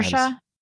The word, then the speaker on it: Saoirse: Cersei, am I saying that right? Or Cersei? I forget. – Saoirse. – Saoirse. I'll never get it.